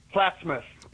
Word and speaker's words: Plattsmouth: Plattsmouth.